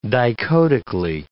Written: dichotically

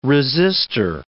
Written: resistor

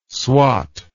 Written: swat

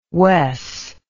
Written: wes

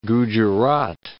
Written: Gujerat

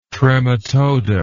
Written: trematoda

